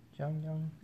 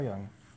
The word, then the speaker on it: Jung: Well, J would be Ya. – Jung. – Jung. – I thought it was Jung. Okay. Carl Jung.